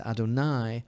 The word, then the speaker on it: Adonai: which is not pronounced the way it is written, but rather it is pronounced as Adonai,